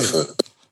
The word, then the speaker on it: Adofo: Adofo. – Say again? – Adofo.